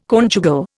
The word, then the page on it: conjugal: Indian